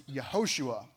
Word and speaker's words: Yehoshua: By the way, his name Joshua is pronounced in the Hebrew as Yehoshua.